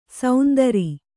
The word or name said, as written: ಸೌಂದರಿ